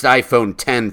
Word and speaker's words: iPhone X: Apple's new flagship phone is the iPhone X, which is pronounced iPhone 10 for some reason.